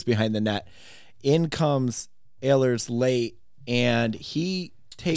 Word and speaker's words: Ehlers: Nikolai Ehlers. – Ehlers.